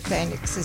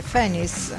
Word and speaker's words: Phoenix: che poi non si dice Phoenix, si dice Phoenix, è francese.